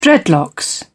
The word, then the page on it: dreadlocks: British English